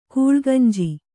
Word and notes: ಗುಲಗಂಜಿ: Kannada

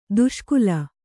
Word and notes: ದುಷ್ಕಾಲ: kannada

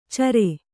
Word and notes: ಚರೆ: care

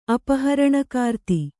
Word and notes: ಅಪಹರಣಕಾರ್ತಿ: apaharaṇakāra

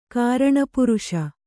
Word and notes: ಕಾರಣಪುರುಷ: kāraṇa puruṣa

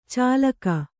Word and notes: ಚುಲ್ಲಕ: cullaka

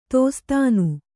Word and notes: ದಾಸ್ತಾನು: dāstānu

